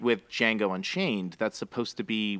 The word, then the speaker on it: Django: And the guy goes, "Django." The D is silent, and the guy goes, "I know, because that's his name."